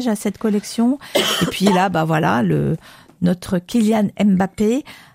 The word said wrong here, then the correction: Mbappé: Non, c'est pas comme ça, c'est Mbappé, la prononciation.